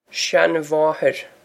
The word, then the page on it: seanmháthair: shan-waw-her